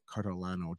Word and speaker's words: Cartolano: The head of Wealthy Inbox himself, David Cartolano. Did I pronounce the last name properly? I always have a problem with last names. – Cartolano, you're pretty close.